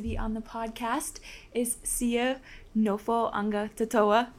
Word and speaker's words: Nofoagatoto'a: Yeah, it's Sio Nofoagatoto'a.